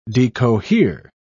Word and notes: decohere: British English

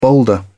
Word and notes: boulder: British English

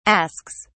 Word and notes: asks: British English

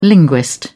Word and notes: linguist: British English

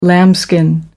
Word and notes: lambskin: American English

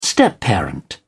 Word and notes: stepparent: British English

